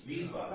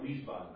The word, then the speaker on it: Wiesbaden: Their W's are not the way that we pronounce W's. There's V's. Wiesbaden. – Right. – We call it Wiesbaden. – Right. – Right? Their W's pronounced as V's.